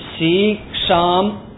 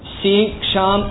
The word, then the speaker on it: சிக்ஷா: சீக்ஷாம் வியாக்கியாசியாமக சீக்ஷாம் என்றால் சிக்ஷா சாஸ்திரத்தை சிக்ஷா என்று சொல்ல வேண்டும் உபனிஷத்தில் தீர்க்கமாக சீக்ஷா என்று சொல்கின்றது